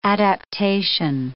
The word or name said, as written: adaptation